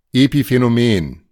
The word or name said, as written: Epiphänomen